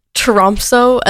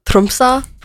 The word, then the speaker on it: Tromsø: In Tromsø, as the Norwegians say, Tromsø, but I'm not a tryhard, so I'm not pronouncing it the Norwegian way.